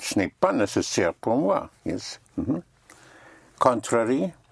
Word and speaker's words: nécessaire: So instead of in spelling, A-R-Y is spelled A-I-R-E. So necessary would be? – Nécessaire. – Nécessaire.